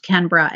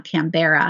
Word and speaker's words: Canberra: And in January of 2020, I visited Australia and did some work with a Brisbane school, which is where I learned that it's pronounced Canberra and not Canberra. I made a fool of myself and got some corrective feedback from the individuals at the session because I said Canberra and they all kind of like laughed at me sort of, but like quietly.